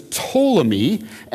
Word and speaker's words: Ptolemy: And then you have this other one, the P is silent, and so the general's name is Ptolemy, and we call it the Ptolemaic Empire, and it's based in Egypt.